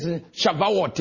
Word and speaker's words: Shavuot: It is Shavuot, if you want to kind of pronounce it the way they do it.